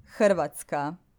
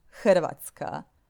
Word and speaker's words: Hrvatska: Hrvatska. Okay, I'm going to spell it out. It's Hrvatska. It starts with an H. Hrvatska.